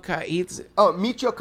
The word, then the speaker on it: Michio: Macheo, you know, the physicist, Macheo. – Michio Kaku.